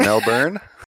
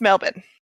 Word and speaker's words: Melbourne: Is it Melbourne? – No. That's far too many vowels. Just Melbourne.